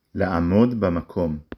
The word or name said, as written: לעמוד